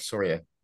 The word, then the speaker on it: Soria: Actually, you got it correct, Soria.